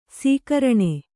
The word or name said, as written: ಸಕಾರಣ